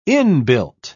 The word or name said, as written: inbuilt